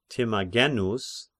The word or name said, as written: Timagenos